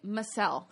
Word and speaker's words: Massell: But it's actually Massell.